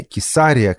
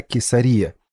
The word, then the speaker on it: Кесария: Не Кесария, как было в предыдущих эфирах, а Кесария. Я проверил, и вы, друзья, абсолютно правы. Ударение именно такое.